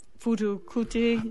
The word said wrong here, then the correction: Fela Kuti: Oh, Fela Kuti.